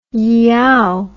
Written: yeow

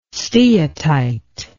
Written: steatite

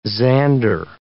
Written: zander

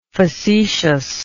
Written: facetiousness

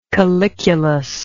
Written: caliculus

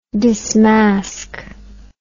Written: dismask

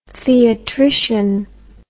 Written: theatrician

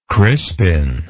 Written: Crispin

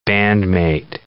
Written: bandmate

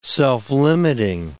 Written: self-limiting

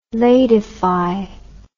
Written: ladify

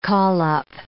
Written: call-up

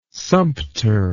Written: sumpter

